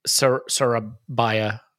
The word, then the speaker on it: Sarabia: Really, really good knowledge there, except I think it's pronounced Sarabia.